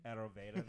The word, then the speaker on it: Ayurveda: Ayurveda Nutrition? – Ayurveda.